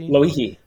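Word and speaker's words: Lauihi (Lōʻihi): I can't pronounce it. I'm sorry. I won't try. – Lauihi. – Lauihi, well, that's close.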